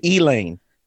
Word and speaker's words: Elaine: You know, in the South, they're going to change the pronunciation. It's the Elaine Massacre.